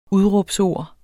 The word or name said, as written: udråbsord